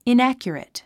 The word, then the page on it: inaccurate: inǽkjərit; イナァキュラト